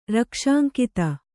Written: ರಕ್ಷಾಂಕಿತ

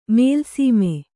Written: ಮೇಲ್ಸೀಮೆ

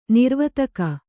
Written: ನೀರವತ್ತಿಗೆ

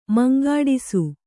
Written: ಮುಂಗೆಡಿಸು

